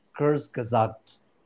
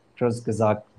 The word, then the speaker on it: Kurzgesagt: Uh, there was one by Kurzgesagt is how they pronounce it. Kurzgesagt,